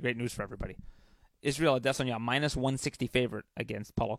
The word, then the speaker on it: Adesanya: So if that's and there's is and that's why do I say Israel Adesanya because that's how Israel says it's pronounced.